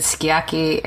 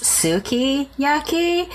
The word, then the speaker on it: sukiyaki: For me, it was the being in a restaurant and I ordered sukiyaki, and I was told that it was actually, it's pronounced sukiyaki.